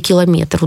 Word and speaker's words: километр: Например, если в слове «километр» ударение падает на «е», то и в словах «дециметр», «сантиметр» будет то же самое.